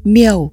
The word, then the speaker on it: miếu: Trong thuyết văn có giải thích chữ miếu đọc là Miêu, rằng tôn tiên tổ mạo, tức tôn kính hình tướng tổ tiên.